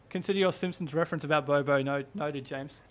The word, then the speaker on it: Bobo: I actually think, watch out for Bobo. – Yeah. – Is that how you pronounce it? Bobo? Or yeah, Bobo. I mean.